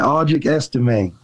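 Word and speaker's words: Audric Estimé: Okay, and help me with the pronunciation. Is it Audric Estime? Is that how you say his last name? – Yeah, Audric Estime.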